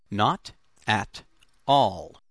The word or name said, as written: not at all